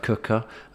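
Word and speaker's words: Quooker: That is Cooker, spelt Quicker, pronounced Cooker.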